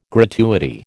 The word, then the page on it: gratuity: American English